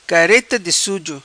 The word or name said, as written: Careta Di Suzo